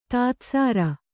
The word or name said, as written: ದಧಿಸಾರ